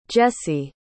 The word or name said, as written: Jessye